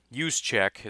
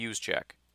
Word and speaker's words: Juszczyk: But since we hired LaFleur, everybody's mind went to Kyle Juszczyk, is how you say his name, I believe. Looks like Juszczyk.